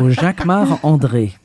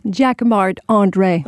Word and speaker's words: Jacquemart-André: You say it like a Frenchman. Jacquemart-André. – Of course, because I'm French. So Jacquemart-André. – And say it so we can understand it. – Jacquemart-André.